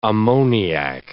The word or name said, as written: ammoniac